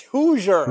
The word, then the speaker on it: Hoosier: It's Hoosier. – Hoosier. – Hoosier. – Hoosier. – Hoosier. Like Hoosier daddy.